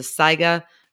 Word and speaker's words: Saiga: But the organization of the week that I really want to give a shout out to is the SIGA Conservation Alliance, and that's SAIGA. That's how you mm-hmm. spell SIGA, even though it's the A is silent.